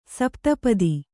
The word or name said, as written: ಸಪ್ತಪದಿ